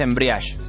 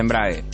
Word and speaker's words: embrague: Pero no se dice embriage, se dice embrague. – Embrague. Embriage no. – No.